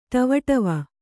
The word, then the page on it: ಡಿವಿಡಿವಿ: ḍiviḍivi